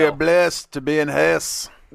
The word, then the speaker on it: Hesse: Shout out to all our folks in Hesse. I learned it's pronounced Hesse. – Hesse. – It's not pronounced Hesse. It's Hesse. Hesse.